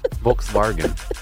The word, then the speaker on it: Volkswagen: If you want to be in on it, Volkswagen.